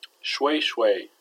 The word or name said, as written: shway shway